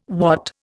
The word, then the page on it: wot: Indian